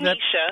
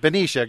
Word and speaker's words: Benicia: Is that, am I pronouncing Benicia. – That? Benicia.